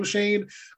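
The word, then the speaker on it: Schoen: I'm excited for Joe Shane. I think he has an insane name because it does not look like Shane. So people are going to be pronouncing it wrong for eternity.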